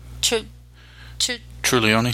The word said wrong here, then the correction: Tonioli: Trulioni. I can't ever say his name. And Craig Tonioli. – Tonioli, thank you.